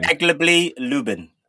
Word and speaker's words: Zagłębie Lubin: Zagłębie Lubin.